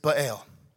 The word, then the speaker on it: Baal: The first one we're going to talk about is probably the most prominent, and his name is Baal. Yes, I'm saying that right.